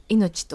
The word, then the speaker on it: 生命: 生命と書いて、はい、命と呼ぶんですけども、